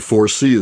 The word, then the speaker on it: ForeSee: And 4C is spelled F-O-R-E-S-E-E, looking ahead, the 4C monitoring device.